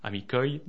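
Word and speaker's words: amikoj: Le pluriel, suffit de mettre le J qui se prononce IE en espéranto à la fin, donc amico, un ami, amicoi, des amis.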